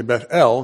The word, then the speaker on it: Bethel: I say Bethel because I was listening to many preachers, and one of them pronounced it that way because he put emphasis on the last syllable, E-L, because that's God's name. And so this is the house of God. And, you know, so there's more emphasis on God than on the house.